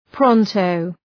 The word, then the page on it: pronto: ‘prɒntəʋ